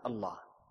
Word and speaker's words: Allah: And then they are brought together, and they are pronounced as Allah.